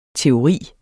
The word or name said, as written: teoris